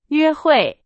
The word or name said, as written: 约会